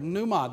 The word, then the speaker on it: pneuma: It's the Greek word pneuma. The P is silent.